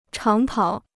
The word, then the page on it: 长跑: cháng pǎo